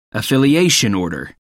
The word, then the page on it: affiliation order: American English